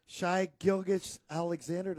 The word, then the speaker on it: Shai Gilgeous-Alexander: Shai Gilgeous-Alexander, did I say it right? – Yes,